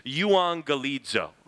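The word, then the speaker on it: euangelizo (εὐαγγελίζω): You pronounce this euangelizo, okay? Euangelizo. It has the sound kind of where you see those two gammas next to each other, that when there's two gammas next to each other, the first one has like an N sound in English.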